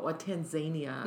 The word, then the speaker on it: Tanzania: and these earrings were from Tanzania, mm-hmm. I learned while I was there. That's the proper way to say it.